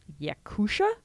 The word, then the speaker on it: Yakutia: I believe it's pronounced Yakutia, Y-A-K-U-T-I-A.